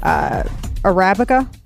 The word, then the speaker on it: Arabica: The Arabica? How do you say that? Yeah, that's right.